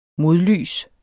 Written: MODLYS

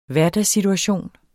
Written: hverdagssituation

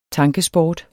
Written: tankesport

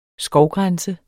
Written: skovgrænse

